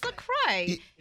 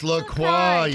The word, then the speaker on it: LaCroix: You know, like the it's LaCroix, right? I'm saying it right. It's LaCroix. – It's LaCroix. Okay.